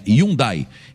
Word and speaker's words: Hyundai: E você sabe que a pronúncia certa não é Hyundai, né? Hyundai.